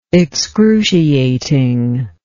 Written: excruciating

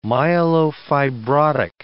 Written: myelofibrotic

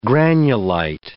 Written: granulite

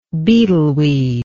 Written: beetleweed

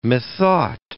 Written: methought

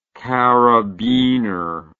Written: karabiner

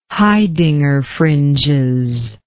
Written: Haidinger fringes

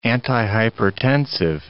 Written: antihypertensive

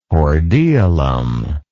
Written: hordeolum

